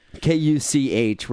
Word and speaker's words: Kuch: So Ronald Kuch, I'm not quite sure how to say his name. I think, I think it's, you pronounced it perfectly. – K U C H. We're just say Kuch.